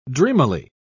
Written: dreamily

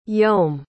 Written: yeom

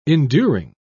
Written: enduring

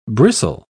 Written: bristle

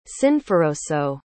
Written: sinforoso

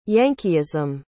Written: yankeeism

